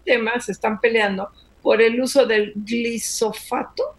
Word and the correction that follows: glifosato: que espero que sí lo esté diciendo bien, porque siempre... – Glifosato. – Ah, ah, fíjate, lo dije mal, glifosato.